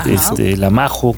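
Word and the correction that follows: Mahou: ¿cómo se dice? Mao.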